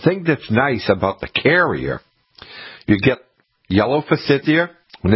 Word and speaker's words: Kerria: But when the forsythia gets done blooming, the next plant that flowers is called carrier. And it's spelled K-E-R-R-I-A, carrier.